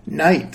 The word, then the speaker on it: night: Night seems irregular because the GH is silent.